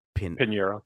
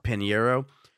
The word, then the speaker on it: Pinheiro: But how do you say her name? Luana Pinheiro. – Pinheiro.